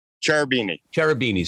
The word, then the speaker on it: Cherubini: Cherubini.